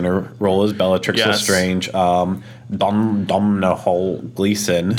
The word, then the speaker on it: Domhnall: Domhnall, yeah.